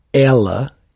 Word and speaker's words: ela: When you say ela, you actually it's like there is an accent on the e. Ela. – It's a little bit more open.